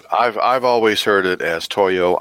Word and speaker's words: Toyo: T-O-Y-O does, in fact, rhyme with yo-yo.